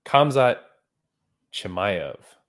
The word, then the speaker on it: Khamzat Chimaev: Kevzat Chemvie. – Oh my God. That's really not close. Kamzat Chimaev. – Chimaev. – I believe that's the pronunciation.